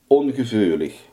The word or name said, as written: ongevoelig